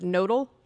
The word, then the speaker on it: Knödel: One, in Germany, the word for dumpling, not all the time, but it often is, I don't know how to pronounce it, knödel, which is obviously...